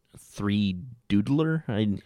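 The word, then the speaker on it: 3Doodler: Basically, a pretty nifty invention came out a while back that was called the 3D three doodler. I don't know how to say it. Basically, it's supposed to be 3D doodler, but they took a D out of it, so it's three doodler.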